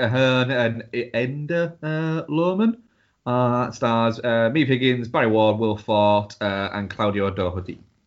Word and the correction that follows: Forte: Will Forte.